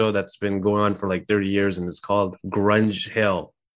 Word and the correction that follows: Grange Hill: if I'm pronouncing it right. – It's called Grange Hill. – Grange Hill, sorry.